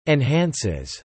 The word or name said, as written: enhances